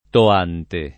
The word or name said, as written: Toante